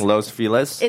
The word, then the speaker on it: Los Feliz: You it's, mean Los Feliz? – It's Los Feliz. That's how you say it.